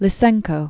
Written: Lysenko

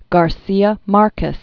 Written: Garcia Marquez

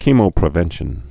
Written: chemopreventive

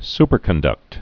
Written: superconductor

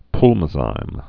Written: Pulmozyme

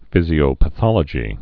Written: physiopathologic

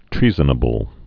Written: treasonable

